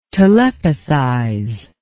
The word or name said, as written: telepathize